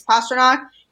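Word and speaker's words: Pastrnak: I think the nickname kind of helps with that too, with calling him Pasta. So that kind of naturally flows with his actual name, which is Pasternak. And, you know, all the commentators say it like that. So I think him having that nickname of Pasta exists for a reason. And part of that is to help people actually pronounce his name right, because that is how you say his name.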